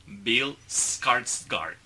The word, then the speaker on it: Bill Skarsgård: Bill Skarsgård.